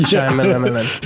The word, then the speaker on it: Shyamalan: Night Shyamalan. – Yeah, that was the correct way to pronounce it the second time.